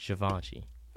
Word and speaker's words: Givenchy: First off, it's pronounced Givenchy.